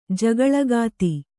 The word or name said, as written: ಜಗಳಗಾತಿ